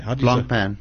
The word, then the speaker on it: Blancpain: Blancpain.